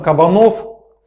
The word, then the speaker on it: Кабанов: Перед отъездом Кабанов, кстати, ударение правильное, наказал Катерине в его отсутствие делать все то, что ему мама сказала.